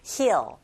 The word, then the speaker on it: heal: 즉, 치유하는 사람들, 즉, 치유자를 가리키는데 heal 할 때 소리 내실 때 좀 길게 하셔야 되죠. heal, heal.